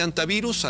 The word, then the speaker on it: hantavirus: ¿Cómo sería la pronunciación correcta de entrada? – En español es antivirus.